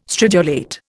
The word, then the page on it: stridulate: Indian